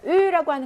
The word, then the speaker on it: eu: 자, 으라고 하는 것은 이제 으라고 할때 아부아 동사의 과거 분사죠. 아부아 동사의 과거 분사 예전에 발음 문제 한번 나온 적이 있어요. 발음 으 이렇게 발음한다라고 했어요.